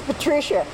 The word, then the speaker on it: Petrecia: Is it Pat or Patricia? – It's Patricia. – Patricia. – And it's spelled P-E-T-R-E-C-I-A.